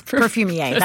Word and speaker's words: perfumier: And he ends up becoming an apprentice to a perfumier, which is, am I saying that right? – Yes, perfumier. That's correct.